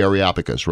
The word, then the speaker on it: Areopagus: Wasn't 17 by the Areopagus? – The Areopagus, right.